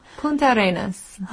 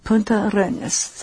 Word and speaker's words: Punta Arenas: How do you say the next one? – Punta Arenas. – Oh, I said Punta Arenas. Does that sound good? – Sounds good to me.